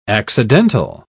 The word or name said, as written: accidental